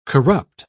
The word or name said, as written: corrupt